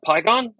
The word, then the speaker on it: Pygon: I am here today with Dave uh, Pygon.